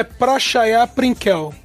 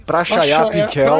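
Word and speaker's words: Prachaya Pinkaew: é o Pratia Prankaev, sei lá como é que se fala isso corretamente, Meu, a, né? A, a pronúncia é Prachaya Prinkel. – Pinkel. Ah, Pinkel. – Prachaya Pinkel, obrigado.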